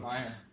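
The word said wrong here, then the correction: Maher: Нет, произносится как Мар.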